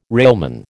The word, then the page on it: railman: American English